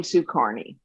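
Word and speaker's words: Kearney: I pronounce my name Sue Carney.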